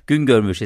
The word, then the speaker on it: Güngörmüş: Güngörmüş. – Güngörmüş ist richtig, ja, richtig ausgesprochen, ja.